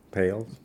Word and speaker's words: pâles: Paillet? – No. – Pâles? – Pâles, flambeaux.